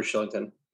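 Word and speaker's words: Kylington: Excellent pronunciation, by the way, of Shillington. He is it is a soft K. – Yeah, I'm an actor, so I know how to pronounce those soft K's. – But yeah, he's Swedish, and I never know in Swedish if I'm supposed to say K or Sh.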